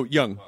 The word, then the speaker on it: Jung: Like as you get older, you kind of, it's that is it Jung? Is that how you say his name? The psych. – Oh, Jung. – Jung, thank you.